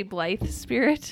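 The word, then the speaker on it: Blithe Spirit: You said Black Spirit. – No. – Oh, did you say Blythe Spirit? – Yeah.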